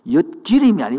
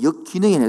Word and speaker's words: 역기능: 역기름이 아니고 역기능이 했습니다. 자꾸 와가지고 은 할매가 자꾸 역기름 역기름 내가 역기름 한 적이 없는데 내가 아무리 발음이 안 좋아도 내가 역기능이 했는데 역기능이 역기능이 자르면 바로 성인 아이가 만들어집니다 뭐라고요?